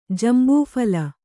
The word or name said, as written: ಜಂಬೂಫಲ